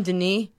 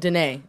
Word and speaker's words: Dene: Dene. – Okay, I'm never going to say Navajo again. Dene.